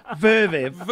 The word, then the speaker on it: Zverev: Verviv. But, it's a silent Z.